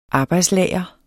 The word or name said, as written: arbejdslager